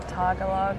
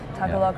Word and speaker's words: Tagalog: It's actually meant to be Bugtong is a Tagalog, I always say that wrong, Tagalog yeah.